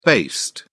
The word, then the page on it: based: British English